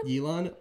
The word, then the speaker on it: Yilan: Don't try to do Yilan like that, okay? – All right. Glad to hear from you. – How is this spelled, by the way? – What? – Yilan? – Yilan, Y-I-L-A-N.